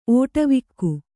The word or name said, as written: ಓಟವಿಕ್ಕು